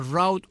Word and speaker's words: route: La palabra Roadmaster en inglés, que es R-O-U-T-E. Esa es la palabra route o route, dependiendo cómo la pronuncias. En ambos modos es correcto, significa ruta.